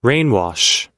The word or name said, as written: rainwash